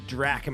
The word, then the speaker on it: Drach: Miss Tierney Drach. Am I saying that right? – Drach. Like Dracula.